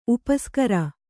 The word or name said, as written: ಉಪಸಾಗರ